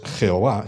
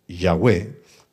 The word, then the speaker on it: Yahweh: Bueno, entonces esta pronunciación no es Jehová, sino Yahweh, porque la transliteración del término hebreo en los ingleses es una J, porque suena como suena en hebreo pero en los españoles es una Y, lo que suena lo mismo que en hebreo.